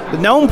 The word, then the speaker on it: GNOME: Live at CSUN 2010, I stopped by the GNOME project table here. And that is right, GNOME, right? Some people call it GNOME, but I'm pretty sure that G is silent.